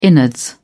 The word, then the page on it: innards: British English